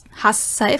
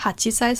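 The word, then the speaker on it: Hassai (はっさい): Yonsai, Gosai, Roksai, Nanasai, Hassai, Vorsicht, nicht Hachisai, sondern Hassai, Kyusai und Jussai.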